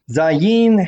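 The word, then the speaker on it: Zay (זה): The Hebrew word Zayin He, pronounced Zay, can mean this or that.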